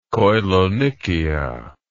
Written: koilonychia

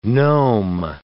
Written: gnome

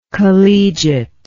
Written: collegiateness

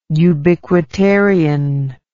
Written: ubiquitarian

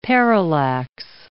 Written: parallax

